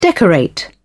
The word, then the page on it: decorate: British English